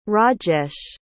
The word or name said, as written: Rajesh